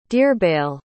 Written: dearbhail

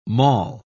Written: mall

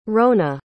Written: roanna

